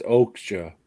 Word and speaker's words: Okja: My number four is Okja, the giant hippo pig from the movie Okja.